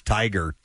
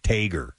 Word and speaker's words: tiger: Here's another one that says, my father-in-law pronounces tiger, Tager.